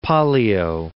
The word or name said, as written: Pollio